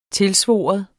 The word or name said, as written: TILSVOR